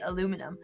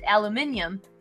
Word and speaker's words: aluminium: American Chemical Society adopted aluminum in 1925, which is why Americans say it aluminum. But in 1990, the International Union of Pure and Applied Chemistry accepted aluminium as the international standard. So aluminium is used by pretty much everybody else in the world, but aluminum is how we say it here.